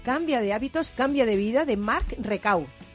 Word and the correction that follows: Reclau: Reclau, sí. – Recau, Reclau. – Eso es.